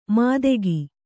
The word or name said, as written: ಮಾದೆಗಿ